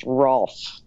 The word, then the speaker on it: Rolf: Yes, it's pronounced Rolf.